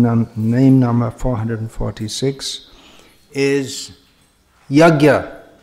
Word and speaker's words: Yajna: Apparently this should be properly pronounced Yajna. Different ways to pronounce this. But in modern Indian languages, the J-N is always pronounced Gya.